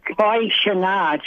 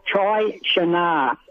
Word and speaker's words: Chiachana: Chiachana. Chai as in chai latte. Chiachana.